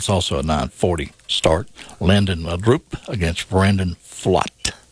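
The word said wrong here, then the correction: Pfaadt: I'm mispronouncing that. Brandon, I looked that up. Fought, I believe. Yeah, it's kind of like fault. Brandon fought.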